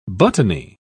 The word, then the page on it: buttony: British English